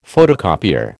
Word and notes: photocopier: American English

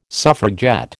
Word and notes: suffragette: American English

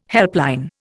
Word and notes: helpline: Indian

